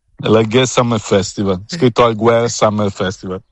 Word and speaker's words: Alguer: Ecco, vedi, Alguer senza la R, si pronuncia senza.